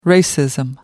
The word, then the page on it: racism: American English